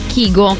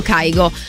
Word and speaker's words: Kygo: Se mi incontrate Kygo, perché può accadere di incontrare Kygo persino, non so, mentre si fa colazione la mattina in un bar, ricordatevi che la pronuncia corretta del suo nome è Kygo, che lui è norvegese, anche se poi tantissimi, anche in Italia, ma soprattutto in America, anche tanti di noi lo chiamano Kygo.